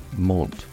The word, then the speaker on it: menthe: Mint is pronounced in French, mont, and spelt M-E-N-T-H-E.